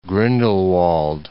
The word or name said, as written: Grindelwald